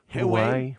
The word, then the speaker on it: Huawei: Huawei. – H-U-A-W-E-I.